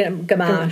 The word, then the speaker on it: Gamache: G- Gamache.